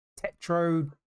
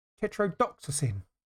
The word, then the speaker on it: tetrodotoxin: a powerful neurotoxin called tetrodotoxin, which can be found in several animals, including pufferfish. He claimed... – Sorry, bro. – Tetrodotoxin.